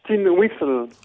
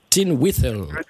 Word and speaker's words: tin whistle: Alors, on prononce ça tin whistle. – Oh, tin whistle.